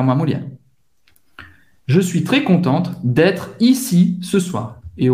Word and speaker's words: contente: Je suis très contente. Eu, eu estou muito feliz. Hein? Ela está muito feliz. Eu estou muito feliz. Para uma menina é contente. Você vai pronunciar o T no final.